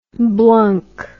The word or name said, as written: blunk